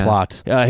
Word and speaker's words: Platte: I bet this guy who has these kind of opinions, I bet he pronounces his name Platte or something like that. – Because It's P-L-A-T-T-E. – Plot. Kent Plott.